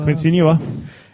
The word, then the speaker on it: Quincy Enunwa: And, and forgive me if I pronounce this wrong. Is it Quincy Enunwa? – Quincy Enunwa.